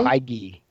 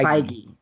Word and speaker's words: Feige: I've heard it pronounced several different ways, but is it Kevin Feige? – Feige. – Feige. – Feige. – Feige? Kevin Feige yeah.